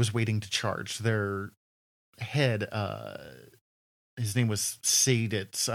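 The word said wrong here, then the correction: Seydlitz: I'm no way I'm pronouncing that correctly. I'm sorry. Sadlitz, yeah. Sadlitz uh,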